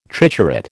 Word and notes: triturate: American English

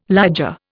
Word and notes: liger: Indian

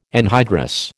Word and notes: anhydrous: American English

